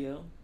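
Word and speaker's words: itch.io: Ichio.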